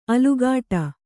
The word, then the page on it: ಅಲುಗಾಟ: Kannada